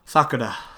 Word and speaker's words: Sakura: Sakura.